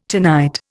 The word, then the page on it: tonight: Indian